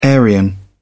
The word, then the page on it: aryan: British English